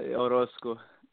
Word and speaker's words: Orozco: Marc, I want to make sure I got the last name correctly. Is that the correct way to say your last name? – Orozco.